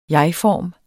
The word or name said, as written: jegform